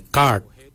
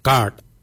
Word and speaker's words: gaad: Yimang is spelled Y-I-M-O-O-N-G, yimang. Yimang is forehead. The next word is head. For head, we say kart. Kart. Kart is spelled G-A-A-D, kart.